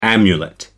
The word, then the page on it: amulet: American English